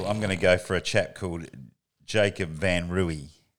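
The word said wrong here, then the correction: Van Rooyen: I told you 9 times the cunt's name and you can't even say it right. Van Ruin.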